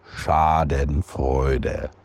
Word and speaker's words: Schadenfreude: Yes, otherwise known as Schadenfreude, which probably I'm butchering that too, but we've had many of our German friends chime in and they say it's pronounced like this. – Schadenfreude.